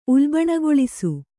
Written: ಉಲ್ಬಣಗೊಳಿಸು

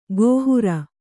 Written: ಕೈಹಾರೆ

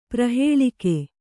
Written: ಪ್ರಹೇಲಿಕೆ

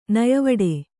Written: ನಯವಡೆ

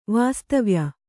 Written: ವಾಸ್ತವ್ಯ

